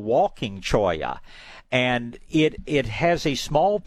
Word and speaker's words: cholla: People down here call it cholla from the Spanish pronunciation.